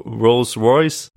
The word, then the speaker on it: Rolls Royce: The British automaker, Rolls Royce.